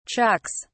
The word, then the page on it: chucks: British English